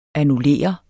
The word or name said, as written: ANNULLERE